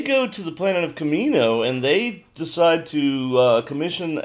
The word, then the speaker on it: Kamino: Kamino is how you pronounce it.